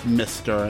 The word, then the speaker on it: Mzztr: Alright, we're going to kick off round one with absolutely brutal band out of New York City called Mr. Mr. is spelled M-Z-Z-T-R and is pronounced Mr., and that's the name of their brand new EP just released recently.